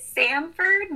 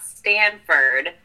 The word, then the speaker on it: Samford: And a little quick note, it is Samford, not Stanford, but everyone gets it wrong. And I actually have a shirt that says Sam, not Stan on it, so.